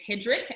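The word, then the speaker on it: Hedrich: Well, if you know how to spell Third Reich, I always say that because my name is Hedrick, and so it's H-E-D-R-I-C-H.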